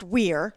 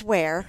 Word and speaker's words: Ware: W-H-E-R-E? – Mm-hmm. Yep. No, it's actually W-E-A-R-E. So you would think it's pronounced Weir, but it's Ware.